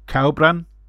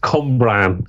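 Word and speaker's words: Cwmbran: Cowbran. – I think it's pronounced Cumbran, but it's my Welsh pronunciation.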